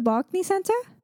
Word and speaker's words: Barclays Center: how's it pronounced? The Barclays Center? – Barclays Center, yeah.